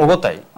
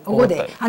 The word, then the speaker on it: オゴデイ: オゴタイ、オゴタイ、オゴデイ、あのね、はい、名前の読み方がなんで違うかって、ジンギスカンとチンギスハンといろいろあるじゃないですか、はい、あれは要するに書いた人たちの文字があの読み方が違うんですよね。漢字で写した時と、それからペルシャ語で写した時と、はい、今のモンゴル人の発音がみんなちょっとずつその方言差があるんですよ。